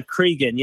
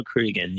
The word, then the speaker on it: Cregan: It's, uh, yeah, Cregan. Yeah, Kenzo Cregan.